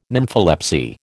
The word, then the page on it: nympholepsy: American English